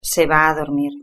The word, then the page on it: se va a dormir: European Spanish